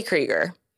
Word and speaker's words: Kreiger: And we have our special guest today, Emily Kreiger.